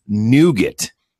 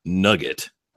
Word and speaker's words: nougat: So it's nougat, not nugget.